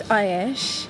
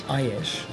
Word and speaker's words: Ayech: Um, it's pronounced Ayesh. Ayesh.